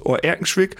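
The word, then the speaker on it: Oer-Erkenschwick: Ja, weil Recklinghausen liegt ja bei Oerkenschwick. – Ja. – Und ich glaube, ich habe das richtig gesagt. Sagen wir, Oerkenschwick liegt im Kreis Recklinghausen. – Ja. Ganz korrekt.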